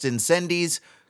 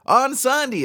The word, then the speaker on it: Incendies: And in case you're wondering, yes, as of 5 minutes ago, I did think it was pronounced Incendies. Go figure, Onsandi is how it's pronounced.